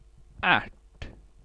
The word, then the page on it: ärt: /æʈ/